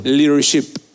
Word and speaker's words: leadership: O sea, y es leadership con P. A ver, pronuncia. Leadership. Excelente. Okay. Porque a veces lo pronuncia sin la P, lo, pronuncia con otra letra y es una mala palabra.